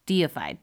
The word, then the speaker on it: deified: Sometimes these ghosts would be defied. Yeah, which I'm like, did I? I don't know if I typed that right. Deified. There we go. I think I've just been saying it wrong this whole time.